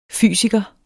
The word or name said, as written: fysiker